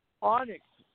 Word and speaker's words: Onyx: Onyx. O-N-Y-X.